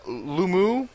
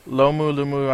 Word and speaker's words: Lomu: Jonah Lumu. – Lomu, I don't know. – L-O-M-U, Lumu.